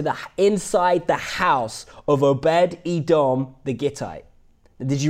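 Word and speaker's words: Obed-Edom the Gittite: Obed Edom the Gittite. Is that right? Is that right, Nigel? Okay. Obed Edom the Gittite.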